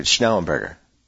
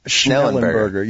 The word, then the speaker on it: Schnellenberger: Well, I saw the rough cut, which the final version has to take out some of the Schnellenberger stuff just for Schellenberger. Wait, Schnellenberger. Schnellenberger. There's an N in there, right? In the beginning. – Schnellenberger.